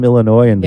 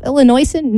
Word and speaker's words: Illinoisan: You know, with the S, it's a silent S. It's so hard. I was thinking myself, how do we call ourselves? Do we call ourselves Illinoisan? No, it's Illinoisan.